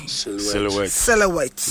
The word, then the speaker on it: silhouette: Silhouette. Yes. – That silhouette in spelling is different from the way pronunciation.